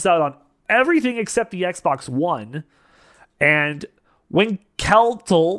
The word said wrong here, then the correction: Winkeltje: the little shop. – Winkelty. – it's spelled Winkeltje.